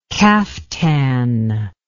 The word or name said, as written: kaftan